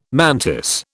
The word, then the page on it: mantis: British English